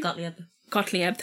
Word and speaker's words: Gottlieb: The project was headed by Sidney Gottlieb, I think that's how you say his name. – Gottlieb, thank you.